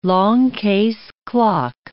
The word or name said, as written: longcase clock